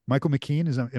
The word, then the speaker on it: Michael McKean: Clue, Michael McKean, am I saying that right? – Sure.